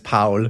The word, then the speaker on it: Paul: I mean, if he's speaking German, it's Paul.